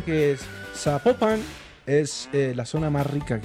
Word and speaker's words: Zapopan: Nos escriben los oyentes y que no se, no se pronuncia Zapopan, sino que se pronuncia Zapopan.